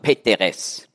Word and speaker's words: Peteres: Und es ist so im Altägyptischen, von den meisten Wörtern weiß man ja nicht, wie sie ursprünglich ausgesprochen worden sind. Und da, wo man nicht die Aussprache kennt, da setzt man einfach E ein. Ja? Darum sagt man Peteres.